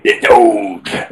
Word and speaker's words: Doge: It's Doge, right? – Doge.